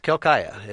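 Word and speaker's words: Quelccaya: It's Quilcaya. It's a Quechua Indian name.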